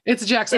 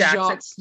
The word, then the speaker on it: Jaques: It's Jax. I think he says Jaques. Jaques.